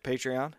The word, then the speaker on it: Patreon: But, you know, we're sitting here talking about me, and a while ago you were talking about, is it Patreon? Am I saying that right? – Yep, Patreon.